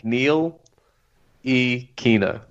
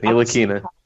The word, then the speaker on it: Ntilikina: You don't even look at the T. As you said. Forget the T. – Just think Neil E. Kina. That's it. Neil Akina.